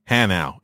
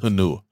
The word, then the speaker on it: Hanau: The 3 years that you're going to be here, it is pronounced Hanau. It is not pronounced Hanua."